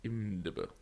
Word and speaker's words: IMDb: I believe it's pronounced IMDb. – IMDb, sorry. Yes.